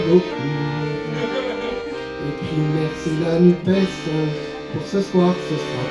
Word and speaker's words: Nupes: Moi, je dis qu'il faut dire Nupes. Si on ne dit pas tout, si on ne prononce pas le S, on oublie un bout.